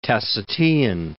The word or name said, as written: Tacitean